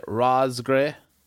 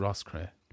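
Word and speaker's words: Roscrea: People say Ross Gray. People say like Ross Cray. It's Ross Cray. – But there's no G in it.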